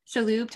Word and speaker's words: Shalhoub: So the ones that I had, the first one is Tony Shalhoub. I feel bad. I think I'm pronouncing his last name wrong. – No, I think that's right. – Shalhoub, Tony Shalhoub from Monk.